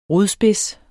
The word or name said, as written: rodspids